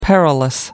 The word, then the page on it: perilous: American English